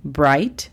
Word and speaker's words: bright: El primero es bright. Bright significa brillante. La pronunciación fonética es B-R-A-I-T. Bright.